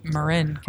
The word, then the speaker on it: Marin: Marin County, for those of you that don't pronounce it, Marin.